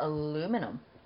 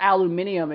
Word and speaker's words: aluminum: Like with the M-U-N-D sound. – Yeah. Almond. Not almond. – How do you pronounce the word aluminum? – Aluminum. Or aluminium if you're British. Aluminum. It's like vitamin versus vitamin. Or vitamin. Vitamin. Same thing. Aluminum is what I would say.